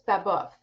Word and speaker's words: Babeuf: So it's Gracious Babeuf, and he named himself that.